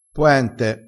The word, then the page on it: poente: dialettale